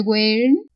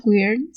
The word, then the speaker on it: Weyr: y el weird. Ah, por cierto, weird Se pronuncia Weir.